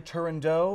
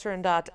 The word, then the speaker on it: Turandot: I personally actually am of the school that it should be pronounced Turandot, as it is based on a Persian fairy tale. And the original spelling, this is super nerdy, but the original spelling was such that it should actually be pronounced Turandot, but it kind of depends on who you ask.